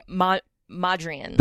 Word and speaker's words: Mondrian: Mondrian.